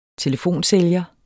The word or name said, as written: telefonsælger